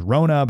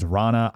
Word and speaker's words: Ronna: I always thought it was Rona, but I guess it's Ronna.